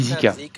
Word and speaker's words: Zika: Comment on dit d'ailleurs en anglais Zika? Zika? – Zika? How do you say that in English? Zika? – Ça se dit Zika.